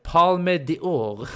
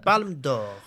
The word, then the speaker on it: Palme d'Or: Parasite vann, nu får du hjälpa mig uttala det här, Palme d'Or. Mm, Palme d'Or.